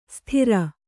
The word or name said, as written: ಸ್ಥಿರ